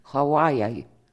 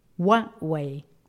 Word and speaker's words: Huawei: Huawei. – Λοιπόν, Huawei.